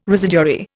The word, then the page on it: residuary: Indian